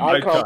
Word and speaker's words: Eikon: One is the, I want to get the pronunciation correct, the Icon Podcast, E-I-K-O-N. – Correct. – The Icon Podcast.